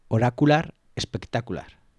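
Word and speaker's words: Oracular Spectacular: El álbum se llama Oracular Espectacular. – No sé si lo he dicho bien. Sí, sí, muy bien.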